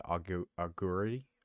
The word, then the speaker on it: augury: Augury.